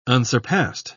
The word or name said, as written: unsurpassed